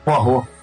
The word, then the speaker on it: Poirot: Porou, parou? Nunca sei, só, só li, né? – Porrou, porrou.